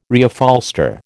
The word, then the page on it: Reupholster: American English